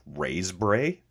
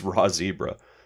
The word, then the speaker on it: Raszebrae: And Raw Zebra, by the way, is spelled RASZEBRAE. I've mentioned them a few times, but I was calling them Raisebray. But it's Raw Zebra.